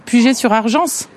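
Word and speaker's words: Puget-sur-Argens: On prononce bien le S, hein, Guillaume? Puget-sur-Argence? Ouais, sur Argence.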